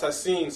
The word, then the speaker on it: Hashan: You know, it's spelled Hashan, so you're saying how it's spelled, but it's pronounced Hasin.